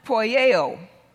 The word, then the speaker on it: poieo: And the first word, do, there is pronounced poieo. Poieo, poieo, poieo.